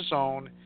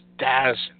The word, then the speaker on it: DAZN: I know it's not DAZN, it's DAZN, okay? I don't know why they're pronouncing it as DAZN, it's DAZN.